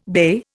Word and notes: bey: Indian

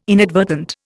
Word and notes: inadvertent: Indian

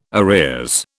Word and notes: arrears: British English

